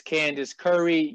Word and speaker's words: Candyce Curry: And I want you guys, Albany community, to welcome tonight's guest who's going to be interviewing with us tonight, Miss Candyce Curry, U.S.